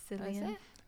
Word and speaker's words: Cillian: because Cillian Murphy wasn't a big name back then, was he? – Not at all. – Yeah, and he still isn't. I think Cillian Murphy is the big name now.